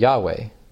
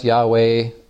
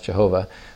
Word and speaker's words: Yahweh: So we know the letters, YHWH, and then we can kind of infer from that, well, maybe it was pronounced Yahweh. Others say maybe it was pronounced Jehovah. That's pretty much wrong. We know it wasn't pronounced Jehovah.